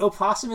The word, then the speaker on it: opossum: They're pronounced the same way. – They're different animals. – Yes, they are. One lives in Australia. – Wait, opossum is pronounced possum? – Yes.